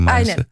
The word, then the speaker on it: Mainz: Aynen, ben Mainz'a mı başvurdunuz, doğru mu telaffuz ettim Mainz'ı? – Aynen peki.